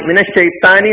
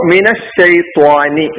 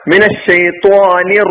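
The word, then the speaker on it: മിനശ്ശൈത്വാനി (مِنَ الشَّيْطَانِ): ത്വ ത്വ അപ്പൊ ത്വാനി മിനശ്ശൈത്താനി എന്നല്ല ശൈത്താനി റജീം എന്ന് പറഞ്ഞു പോകാറുണ്ടെങ്കിലും അത് തെറ്റാണ് ത്വാനി അപ്പൊ മിനശ്ശൈത്വാനി നൂന്ന് കസറാണ് മിനശ്ശൈത്വാനി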